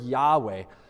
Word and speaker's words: Yahweh: It's the word Yahweh. And Yahweh, maybe you've never heard that word before, but that is, um, at least in our English language, how we would pronounce the proper name of God.